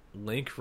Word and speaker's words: Link: Link rhymes with dink.